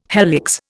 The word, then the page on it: helix: Indian